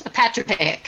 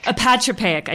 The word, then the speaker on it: apotropaic: So you refer to such things by what has become a new word in my vocabulary, apotropaic. Am I saying that right? Almost, apatropaic. Apatropaic. I just did it wrong.